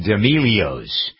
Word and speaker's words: D'Amelio: So now some of Doug's Dirt features a family called the D'Amelios. Who are these D'Amelios people? – The D'Amelios, is that how you pronounce it? – D'Amelio.